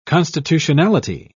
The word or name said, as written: constitutionality